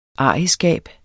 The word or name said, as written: arrigskab